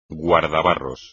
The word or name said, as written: guardabarros